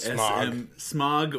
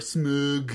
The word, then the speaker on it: Smaug: However you pronounce it, Smaug or Smug. – Smaug. – S-M-A-U-G. So there's some, a lot of people argue over that. But Smaug is how I always called him.